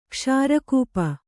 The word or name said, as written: ಕ್ಷಾರಕೂಪ